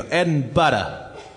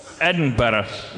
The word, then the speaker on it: Edinburgh: Well, you say it like butter. You go Edinburgh. – Edinburgh.